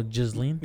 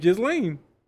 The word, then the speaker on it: Ghislaine: I'm telling you, Ghislaine. Right. Jizz, like the word jizz.